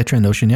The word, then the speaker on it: Trandoshan: Because, well, apparently there's a Jedi that's, how do you pronounce it? Is it Trandoshan or whatever? Mm-hmm. Whatever, yep. Bosk is. – Like Bosk, yeah, Trandoshan, yep.